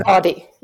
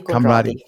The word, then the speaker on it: Conradie: How do you say your last name? Conradie or is it Conradie? – Conradie. Yeah, Yolande Conradie.